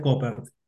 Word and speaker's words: Koppert: Lo pronunciaste perfectamente. Se pronuncia Koppert.